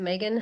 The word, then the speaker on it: Meagan: So Megan Hubbard Coaching is my handle, and Megan is spelled MEAGAN.